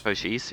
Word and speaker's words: Cochise: Cochise, yeah.